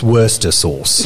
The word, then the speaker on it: Worcestershire: I think it's time to go back to war with England so we can finally just determine as Americans what Worcestershire sauce is pronounced like. – It's Worcester sauce.